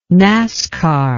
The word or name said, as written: nascar